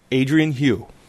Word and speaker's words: Adrienne Hew: That's spelled A-D-R-I-E-N-N-E-H-E-W, Adrian Hugh.